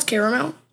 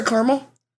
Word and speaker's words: caramel: Pronounce it with two syllables, caramel. No, sorry, caramel.